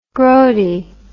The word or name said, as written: groady